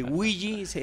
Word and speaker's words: Ouija: Eh, Ouija es como lo pronuncian en Estados Unidos.